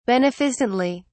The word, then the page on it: beneficently: British English